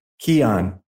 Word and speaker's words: ceann: So Sean in Irish or in head, in Gaelic, it is also Kian, which is spelled C-E-A-N-N, meaning head in Gaelic, which likely comes from or is related to Con, K-A-H-N, and Cohen.